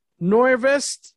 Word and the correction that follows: Norvest: Holdings. Is that correct? – It's pretty close. Norvest Norvest Holdings.